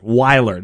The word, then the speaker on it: Weiler: Weiler, not Wydell. Weiler. Sorry, my bad.